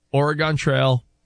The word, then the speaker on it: Oregon: But I do remember loving Oregon Trail, number and word munchers and...